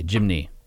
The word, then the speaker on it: Jimny: J-I-M-N-Y, Jimny.